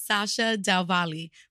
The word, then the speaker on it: Sasha Del Valle: Sasha Del Valle.